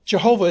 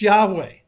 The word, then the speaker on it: Yahweh: But folks, Jehovah is not the name of God. The scholars tell us that the more likely and proper pronunciation of God's name is Yahweh.